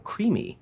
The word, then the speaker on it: creemee: maple creamy, pronounced oh. creamy, but very much its own entity, and so it's spelled C-R-E-E-M-E-E.